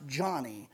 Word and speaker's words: Joni: Her name looks like her name is Joni, but it's pronounced Johnny.